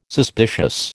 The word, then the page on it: suspicious: American English